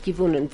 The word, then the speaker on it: gewonnen: Ich habe das gewonnen. Was ist gewonnen? How, how do you say I won it? – Gewonnen. – Gewonnen. gewonnen whatever.